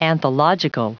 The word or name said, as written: anthological